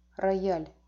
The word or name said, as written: rojali